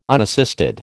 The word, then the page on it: unassisted: American English